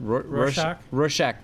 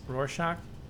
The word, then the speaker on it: Rorschach: Rorschach test. – Rorschach.